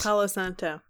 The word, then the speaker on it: Palo Santo: Some of the Santo Paulo wood. And I was like, that's so sweet. – Palo Santo.